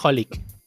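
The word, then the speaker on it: xxxHolic: Se pronuncia Holic. – Holic, pues. Sorry, sorry, sorry, jefecito. Pero sí. – No, no, o sea, me refiero a que las X no se pronuncian.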